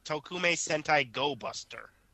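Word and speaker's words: Tokumei Sentai Go-Busters: The 36th Super Sentai series is titled Tokume Sentai Go Buster. – Wow, you actually pronounced that right.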